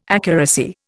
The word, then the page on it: accuracy: Indian